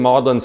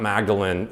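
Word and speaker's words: Magdalen: On Thursday nights, they would meet in Lewis's big Maudlin sitting room. By the way, Maudlin is pronounced not Magdalene here, even though it looks like Mary Magdalene, but Magdalene is the pronunciation at the Oxford and Cambridge colleges.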